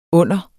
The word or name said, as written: under